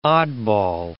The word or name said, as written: oddball